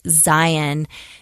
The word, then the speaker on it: Zion: So, for instance, in Zion, there is, and that's the correct pronunciation for Utah.